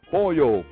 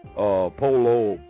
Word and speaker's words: pollo: P-O-L-L-O. Of course, you know that when you have the double L's, you normally say yo. You know what I'm saying? You pronounce it with a Y. So it's pollo, like me llama, me llama, me llamo. You know what I'm saying? You don't say polo, or me llamo.